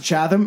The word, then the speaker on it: Chatham: I'm going to pronounce it Chatham.